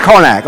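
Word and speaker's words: Carnac: Do we agree it's Karnak? Okay. I just want to put an extra R in there. The great Karnak. Okay. For those of you who remember the great Karnak, you remember the joke.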